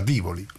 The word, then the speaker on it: Tivoli: E non solo si chiama Tivoli, si pronuncia Tivoli. È scritto Tivoli e si pronuncia Tivoli, cose che in Scandinavia è impossibile leggere.